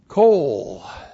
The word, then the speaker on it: kol: This is the noun kol joined by a makef to the plural of the noun goy, the plural is goyim, plus the preposition men, prefixed to the adverb sabib. Kol. K-O-L, kol.